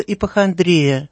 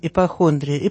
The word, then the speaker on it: ипохондрия: Вообще-то в психиатрии есть такое понятие, называется ипохондрия. В народе часто говорят с неправильным ударением ипохондрия. Ипохондрия.